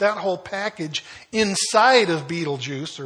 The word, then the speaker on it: Betelgeuse: We want to say Betelgeuse because of the movie, but it's Betelgeuse is the way you pronounce it.